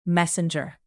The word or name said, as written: messenger